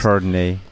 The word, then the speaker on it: Chardonnay: Ah, wie, denn, wie, wie, spricht man das denn perfekt und richtig aus? Chardonnay. – Chardonnay. – Chardonnay. Chardonnay. – Ja, ja.